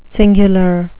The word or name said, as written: cingular